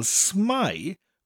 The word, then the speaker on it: smai: So, for example, smai rhymes with the English word spy.